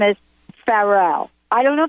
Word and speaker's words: Pharrell: It's Pharrell.